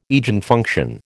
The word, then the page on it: Eigenfunction: American English